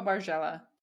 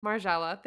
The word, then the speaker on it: Margiela: Maison Margiela. – Oh, Margiela. – Margiela. Thank you. – Is that how I've heard it? Margiela, I think. – Margiela.